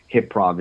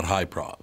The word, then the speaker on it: Hyprov: It's absolutely, the show, Hip-Prov, is absolutely hilarious. – Oh, so it's pronounced Hip-Prov, not High-Prov.